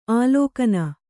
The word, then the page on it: ಆಲಿಗಣ್ಣು: āligaṇṇu